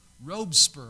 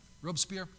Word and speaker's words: Robespierre: Robespierre, I think is how you say it. Robespierre.